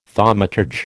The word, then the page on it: thaumaturge: American English